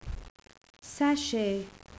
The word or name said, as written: sachet